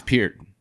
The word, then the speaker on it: Peart: I've also heard his name pronounced Pert. Is that not right? – Pert is like how the Americans say it. It's actually... – Pert Plus. – It's Peart.